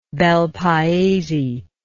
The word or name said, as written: Bel Paese